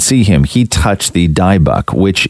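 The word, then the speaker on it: Dybbuk: Come in and tell me how to say this word. It's D-Y-B-B-U-K. How do you say that? – Wait, point to it. Oh. Dybuk. – Dybuk.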